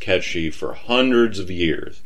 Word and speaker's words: Cait: Ketch, or as a lot of people pronounced it, Kate, is spelled C-A-I-T.